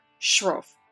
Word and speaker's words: Sroufe: And the author is Robert Sroufe.